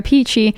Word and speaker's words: Peachy: It's your girl, Sarah Peachy, rhymes with Dici.